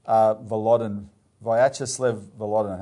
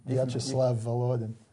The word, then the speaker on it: Vyacheslav Volodin: But there's a new G8 where I want to quote the Speaker of the Russian State Duma, Vyacheslav Volodin. Have I pronounced that right? – Not quite, but it doesn't matter. – Okay. – Vyacheslav Volodin. – There you go.